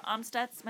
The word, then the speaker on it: Amstutz: My username on all those platforms is at Sandra Amstutz.